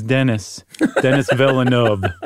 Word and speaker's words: Denis Villeneuve: His name is Denis Villeneuve.